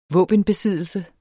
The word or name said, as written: våbenbesiddelse